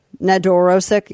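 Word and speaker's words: Nedoroscik: How do you say his last name? Steven Nedorosik? – Sounds right. – Nedorosik, yeah. So he's 25 years old, but he looks way younger.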